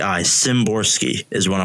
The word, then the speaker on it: Szymborski: the S and the Z are together in that, I think that means uh, it just pronounced like a Z. So it's like Zimborski.